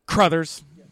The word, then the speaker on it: Crothers: No, it is Brothers with a C. – Crothers.